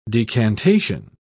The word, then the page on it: decantation: British English